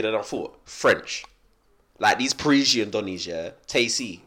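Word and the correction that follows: Tayc: or Tace, whatever his name is. – It's Tyke. – Is that how you say it? – It's Tyke, yeah. – Oh, it's Tyke. – Yeah, he's amazing. – Taysi. – I thought it was Taysi this whole time, so fair enough.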